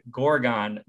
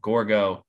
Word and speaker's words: Gorgo: I just want to say the emphasis, I believe, is on the first syllable of Gorgo, not Gorgo. – Gorgo would be the French pronunciation. Yes. This is the British pronunciation, Gorgo.